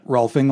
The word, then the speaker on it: Rolfing: Rolfing like golfing.